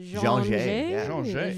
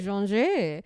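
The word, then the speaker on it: J'an J: J A apostrophe N, then space capital J. The Jean J. – Jean J.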